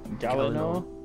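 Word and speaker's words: Gielinor: But is it Galino or Galino? – Oh, it's Galino. – Galino. It's G-I-E. – I'd never got that, to be honest. I just know L-I-N-O.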